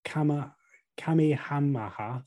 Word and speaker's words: Kamehameha: King Kamehameha I.